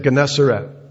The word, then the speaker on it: Gennesaret: The sea that we're talking about, it's pronounced, as I said, Gennesaret.